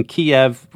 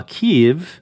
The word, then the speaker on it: Kyiv: So we talked all about the pronunciation of Kyiv, for instance, that pronunciation Kyiv and the spelling K-I-E-V, that reflects an older Russian standard, while Kyiv and the spelling K-Y-I-V do a better job of representing the Ukrainians' own name for their capital city in their own language, Ukrainian.